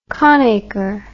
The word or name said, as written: conacre